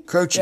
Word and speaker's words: Croce: Jim Croce? – Croce? – Croce.